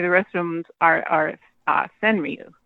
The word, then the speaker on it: Senryu: How do you say Senryu? – Senryu? Is that how you say it? – Yeah, that's how I say it. Senryu.